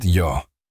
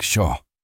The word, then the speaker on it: yo: Here, the letters L and Y are pronounced like the English eshe sound. So the word yo, meaning I, comes out sounding like sho.